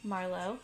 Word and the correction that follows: Malort: Oh my God! – What is it? – Malort. – Malort. That's what it is.